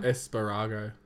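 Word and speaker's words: Espargaró: Espargaro. – I'm pretty sure he did. Espargaro? Haven't we been saying it wrong the whole time? – Espargaro.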